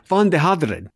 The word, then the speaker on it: Van de Haterd: How do we pronounce that, Bas? – Van de Haterd.